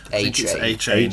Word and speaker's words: Achane: Is it Achane? – A-Chain. – A-Chain. – Yeah. – Oh, that's so much cooler than Achane.